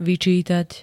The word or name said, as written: vyčítať